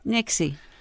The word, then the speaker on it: Nixa: You say the town is Nixa, but nope. If you want people to know that you have never been here, you call it Nixie.